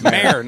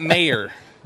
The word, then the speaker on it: mayor: Do you pronounce your job title as if it rhymes with hair or rhymes with payer? – I would say hair. – Yeah, mayor. In your face. I told you it's mayor, not mayor.